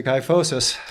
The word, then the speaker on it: kyphosis: I can see the kyphosis. God, on my case, I'm sure I mispronounced that. But no, that was right on.